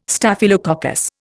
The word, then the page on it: Staphylococcus: Indian